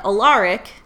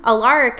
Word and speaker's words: Alaric: And Alaric, did I say that right? – Mm-hmm. – Alaric comes up and he's kind of like looking at Mayor Lockwood, like, why is he taking these kids outside?